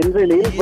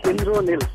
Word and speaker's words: Indranil: Yeah, yeah, this is Indranil. But because I'm a Bengali, so the name will be like Indronil.